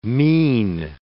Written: mean

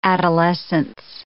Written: adolescence

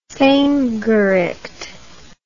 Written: vehmgericht

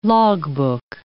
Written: logbook

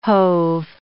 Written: hove

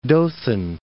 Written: Dothan

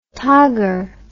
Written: togger

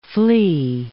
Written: flee

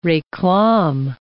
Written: reclame

